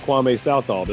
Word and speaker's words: Kwame Southall: And the star of the Hunters from last week's game, Mr. Kwame Southall.